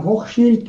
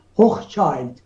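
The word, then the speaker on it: Hochschild: و همچنین شخصیت های دیگری مانند آقای آدم هوخشیلد که آمریکایی‌ها بهش میگن هوخچایلد. واقعیت اینه که این آلمانی اصله و تلفظ اسم اون به نام هوخشیلده،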